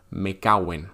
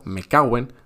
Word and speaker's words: me cago en: Ah, algo importante es que cuando utilizamos esta expresión malsonante tendemos a pronunciarla como si fuera una única palabra, me caguen. Por supuesto, no es correcto escribirlo así, pero si lo pronuncias de esta manera que te digo, me caguen, vas a sonar como un auténtico hablante nativo.